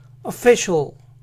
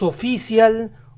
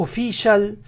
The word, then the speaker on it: official: La primera parte es a fe, a fe, a fe. No pronuncien tan marcadamente las vocales. Solo digan a fe, a fe. La A tiene que ser lo más corta posible. A fe. Entonces la palabra completa se pronuncia official, official, official. ¿Notan cómo las vocales tienen sonidos diferentes a las que usamos? No es oficial u official, sino official, official.